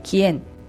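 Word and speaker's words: quién: あとそうですね、誰と住んでるのと聞きたい場合は誰々と一緒にという意味の前置詞が CON と書いてコン、誰という疑問代名詞は QUIEN で E の上にアクセント、それで帰縁、それなんで君は誰と住んでいるのだったらコンキエンビベスだし、あなたは誰と住んでるんですかというんであればコンキエンビベウステというふうになります。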